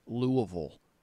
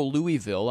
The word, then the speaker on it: Louisville: I have transitioned over to pronouncing it Louisville. I do it like the locals. I know you go Louisville. I'm not saying you're wrong.